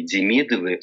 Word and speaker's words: Демидов: Я обнаружил даже, что во французской переписке итальянские Демидовы стали значок ударения на первый слог, чтобы не перепутали, чтобы это было всегда Демидов.